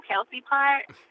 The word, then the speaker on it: Kelcey: But I'm like, as long as you can get the two-syllable Kelcey part, I'm cool.